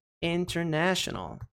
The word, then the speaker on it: international: E pode ver que o ter, a sílaba com T, não é a sílaba forte. Não é a sílaba tônica. Então, não é international. International. International. Não. É international. International. Então, né? É a sílaba tônica, ok? Nesse caso, o padrão se aplica, esse padrão de pronúncia se aplica.